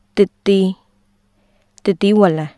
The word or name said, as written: သစ်သီး/သစ်သီး၀လံ